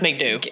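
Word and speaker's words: MacDubh: It's McDoo D-U-B-H. – Yeah, but that's still it's pronounced McDoo.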